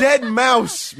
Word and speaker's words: Deadmau5: It's Dead Mouse, man. Dead Mouse. – Oh, man. – All right, hold on a second. It's spelled DEADMAU5. That's Dead Mouse? – Meow.